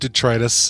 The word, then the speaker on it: detritus: It'll flush some big detritus. Is that detritus? – Detritus. That's right. – Detritus. Yeah. I did say it right.